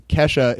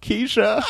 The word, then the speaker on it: Kesha: You're misguided, like thinking Kesha is pronounced Keisha.